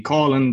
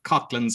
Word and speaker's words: Coughlan: I actually get the same with my name, because mine kind of looks like Coughlan, and people say, is it Coughlan? But it's pronounced in my city, Colin, but Coughlan somewhere else.